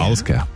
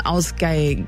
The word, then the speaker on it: Ásgeir: Der Sänger heißt Ásgeir. Würdest du auch so aussprechen, oder? – Ja. Ásgeir. Vielleicht hat er noch ein kleines I dazwischen, so ein Ásgeir.